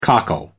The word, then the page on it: cockle: American English